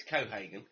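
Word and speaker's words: Cohaagen: Pronounce it wrong. – Yeah, it's Kohagen.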